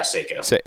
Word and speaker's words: Seiko: Yeah, Seiko.